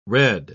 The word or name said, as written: redd